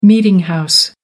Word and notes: meeting house: American English

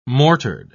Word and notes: mortared: British English

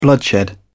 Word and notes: bloodshed: British English

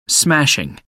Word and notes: smashing: American English

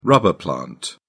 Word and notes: rubber plant: British English

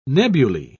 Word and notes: nebulae: British English

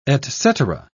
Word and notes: etcetera: British English

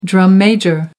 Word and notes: drum-major: American English

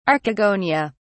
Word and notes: archegonia: British English

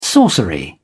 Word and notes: sorcery: British English

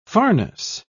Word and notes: farness: British English

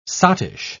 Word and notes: sottish: British English

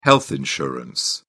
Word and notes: health insurance: British English

